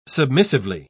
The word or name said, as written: submissively